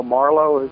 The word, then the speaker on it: Marlowe: Well, Marlowe is the way it's pronounced.